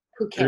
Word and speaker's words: Phuket: We got to go to Chiang Mai, and we went all the way down to Phuket and Koh Samui, but a beautiful, beautiful. Yeah. – You're the first one to say it right. – Phuket. Really? – And it's a lot of people call Phuket.